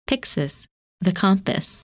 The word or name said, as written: Pyxis